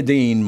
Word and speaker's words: Dean: And Wilhelmina Dean, my grandmother and my mother. How'd you like this one, Tom? My mother grew up with the last name of Dean or Dane. They pronounced it Dane, same spelling.